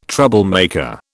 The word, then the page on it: troublemaker: British English